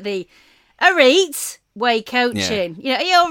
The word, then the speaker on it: Arete: It's A-R-E-T-E. Yeah. – Wait. – Arete way.